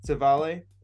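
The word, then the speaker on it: Civale: And how did you pronounce the Cleveland pitcher's name? How do you pronounce Savali. – It? Savali. Okay, that's almost how I had it in my head. Okay. I wanted it to be more like a Savalier, but I couldn't really make that work.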